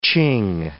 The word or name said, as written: Ching